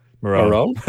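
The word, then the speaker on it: Morrone: Moron.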